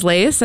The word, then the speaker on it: Lace: My name is Lace, and I'm from Lydia Place. I am the event coordinator there. – I appreciate that your name rhymes with your business.